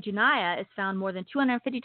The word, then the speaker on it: Junia: Junia, that's J-U-N-I-A. It's pronounced Junia, was identified by Paul as an apostle in Romans 16:7.